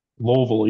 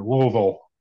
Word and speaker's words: Louisville: Louisville. You gotta say it like your mouth's full of peanut butter. Louisville.